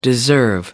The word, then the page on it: deserve: American English